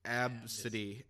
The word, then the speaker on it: Abcde: her name was spelled A, B, C, D, E. – Oh, I remember that. – And her name is pronounced Absidy. Absidy.